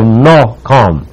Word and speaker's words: nakam: We would pronounce it as if it were N-A-W-K-H-A-M, nakam. It is a word that analytically means to sigh or to breathe strongly.